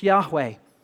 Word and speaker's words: Yahweh: and what's the pronunciation? It's Yahweh.